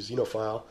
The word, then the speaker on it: XXXenophile: And then a little later during the Smut Glut, he was doing Xenophile, which is spelled XXXENOPHILE, Xenophile, right?